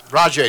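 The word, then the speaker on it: Rajai: Raja Davis, Hector Santiago, Ryan O'Rourke, and Devin Mesoraco. – Rajay Davis.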